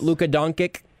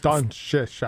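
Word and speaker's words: Luka Dončić: Luka Doncic? Is that Luka Doncic? I don't know. This Don Shush, I believe. – Yeah, more like Don Shush.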